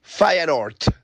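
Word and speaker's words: Feyenoord: Aquí les va la verdad de cómo se pronuncia el equipo de Santiago Jiménez. Fireort. Fire Fireort.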